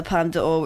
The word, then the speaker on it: Palme d'Or: I think things that go for the Palme d'Or as well. Did I say that right? – Yes, you did.